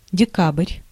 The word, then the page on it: december: /dɛˈsɛmbɛr/